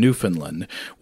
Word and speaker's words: Newfoundland: Perutz writes, quote, "The locality eventually selected for building the prototype was Corner Brook in Newfoundland," where I said it right this time.